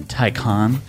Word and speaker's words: Taycan: I think you mean Taycan. – I can never get the name right. – You read it, you look at it with your eyes. – Yeah. – And it says Taycan. But the German in me says Taycan.